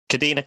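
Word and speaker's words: Kadeena: How do you pronounce her name? Kadena? – Kadena Cox.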